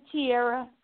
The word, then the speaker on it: Tiara: Oh, pronounce my name Tiara, and that's Tiara.